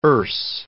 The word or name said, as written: Erse